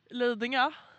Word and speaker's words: Lidingö: Lidingö.